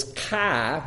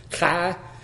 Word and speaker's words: chi: P-H-I, so that's a P-H sound. The X is chi, which is done kind of gutturally, chi, which is a C-H, that kind of sound. And then psi, P-S-I, for psychology, P-S sound.